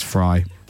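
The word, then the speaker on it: Frey: My sister-in-law says it's Fry. She says it's definitely Fry. Why would I say Fry then? Why? Because I'm normally pretty good at these things. – It's the way it's spelled, I think, because it's F-R-E-Y, isn't it?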